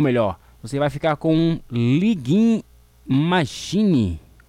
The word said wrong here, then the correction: Luigi Magnini: É assim mesmo que é assim, locutor, que se pronuncia é Luigi Magnini. Isso, agora sim, hein?